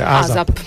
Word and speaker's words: ASAP: Come lo pronunciate? – ASAP. – ASAP. – Alla vecchia. – Alla vecchia. ASAP.